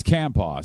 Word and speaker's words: Campau: Is it Campaw? I say it wrong every time. It's Campaw, C-A-M-P-A-U.